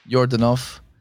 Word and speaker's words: Yordanov: Christian, C-H-R-I-S-T-I-A-N, like the religion. – Yeah. – Yordanov, Y-O-R-D-A-N-O-V, like you had enough.